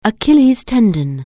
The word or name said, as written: Achilles' tendon